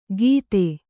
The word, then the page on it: ಗೀತೆ: gīte